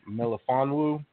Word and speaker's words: Melifonwu: There was one that I saw them really going on and on about, but do you know how to pronounce that? Obi Melifonwu, I think. – Melifonwu.